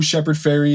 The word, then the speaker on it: Shepard Fairey: Shepard Fairey is.